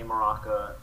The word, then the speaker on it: Mrocka: Um, it's just my name, Anthony Mrocka, M R O C K A dot com.